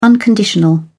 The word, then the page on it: unconditional: British English